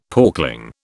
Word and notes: porkling: British English

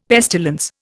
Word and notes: pestilence: Indian

